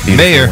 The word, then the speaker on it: Mayer: Yeah, he remember Mayer. Women. Let's shame him. – Rhymes with player.